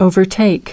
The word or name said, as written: overtake